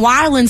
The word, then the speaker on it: wildin': Did I say that right that time? – Yeah, you did. – Good job. – It's bad when I have to tell you how to say wildin'.